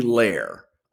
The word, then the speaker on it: Lehr: How do you like to pronounce your name? Do you like it Lear or do you like it Lair? Or answer to all of it because it should be Lair, I think. – Yeah. Yeah. – But for some reason, my hillbilly family, we all pronounce it Lear.